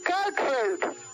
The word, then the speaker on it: Gertrude: No, Gertrude.